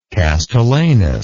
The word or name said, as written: castellanus